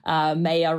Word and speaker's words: Maya: I have a strange Australian pronunciation of my name. – I didn't know that was an Australian thing, huh? Maya. Okay. – Yeah, it's like the month of May.